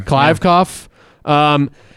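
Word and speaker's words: Kliavkoff: The new commissioner, George, I don't know how you pronounce this, Klyavkov? Can I get a ruling on that? – I think you nailed it. – Klyavkov.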